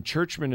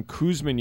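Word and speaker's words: Czuczman: And Churchman is spelled C-Z-U-C-Z-M-A-N. I was calling him Kuzman yesterday, and I'm like, I don't remember a Kuzman on the Bridgeport last year, but I remember this guy.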